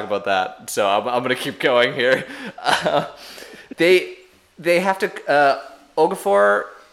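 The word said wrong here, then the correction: Ogakor: Am I saying this right? – Ogacor.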